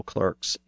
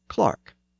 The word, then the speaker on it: clerk: Now, if you're listening to this podcast in Britain and in some other places as well, you may be getting annoyed by my pronunciation of this word as clerk, because in much of Britain, the word is pronounced as Clark. And the word spelled D-E-R-B-Y is Derby in the US, but is Darby in Britain.